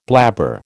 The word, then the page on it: blabber: American English